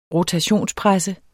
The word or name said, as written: ROTATIONSPRESSER